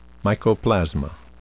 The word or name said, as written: mycoplasma